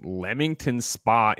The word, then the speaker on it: Leamington Spa: Playground Limited is a British video game developer based in Leamington Spa, England. – Greg, that means. First of all, you pronounced it correctly. I feel like you were going to say Leamington Spa, but you know no, what?